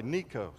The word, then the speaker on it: Necho: After this, when Josiah had prepared the temple, Necho, king of Egypt, and that is how you pronounce it, by the way, Necho, sounds like a mafia boss.